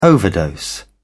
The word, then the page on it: overdose: British English